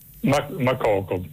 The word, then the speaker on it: Makoko: Mokoko con l'accento? – Makoko, sì, non so se è con l'accento finale alla francese, Makoko, però probabilmente siccome quello è un paese anglofono sarà in maniera diversa.